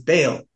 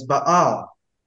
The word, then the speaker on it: Baal: By way of note here, there's you may be familiar with the name of this false god as Baal. But the two A's there are pronounced, you have to pronounce it with their short sound, so it's Baal.